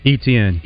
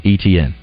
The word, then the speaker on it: Etienne: Etienne. – Etienne. Thank you. – You know, most, and it's one of those names where, depending on the family, like we talked about, it can be Etienne or Etienne.